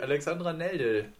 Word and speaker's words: Alexandra Neldel: Sie heißt wirklich Alexandra Neldel. Da hätte ich sie einfach nur richtig aussprechen müssen.